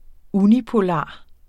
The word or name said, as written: unipolar